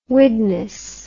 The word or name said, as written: Widnes